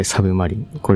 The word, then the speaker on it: 魚影: 2023 年、国鉄の、ま、魚影と、書いてサブマリン。これは読めないので5 位。